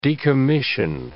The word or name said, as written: decommission